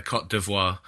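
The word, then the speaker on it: Côte d'Ivoire: Is that how you say it? Cote d'Ivoire? – Yeah, the Cote d'Ivoire.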